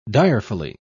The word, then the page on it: direfully: British English